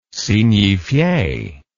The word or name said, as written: signifie